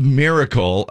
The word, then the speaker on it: Myrkl: Now, it's called Miracle, but it's spelled really weird. It's like M-Y-R-K-L.